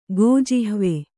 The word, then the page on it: ಗೋಜಿಹ್ವೆ: kannada